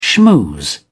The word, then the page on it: schmooze: British English